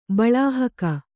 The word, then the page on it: ಬಲಾಹಕ: balāhaka